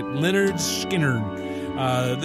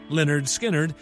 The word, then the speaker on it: Lynyrd Skynyrd: How do you pronounce it? Lynyrd Skynyrd. – Lynyrd Skynyrd? – Well, back in Indiana, we used to say Lynyrd Skynyrd. You can go see Lynyrd Skynyrd? – Reduce it to two syllables? – Yeah, it's kind of one.